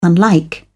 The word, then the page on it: unlike: British English